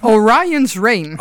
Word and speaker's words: Orion's Reign: Hoe spreek je het eigenlijk uit? De Reign? Ja, Orion's Reign.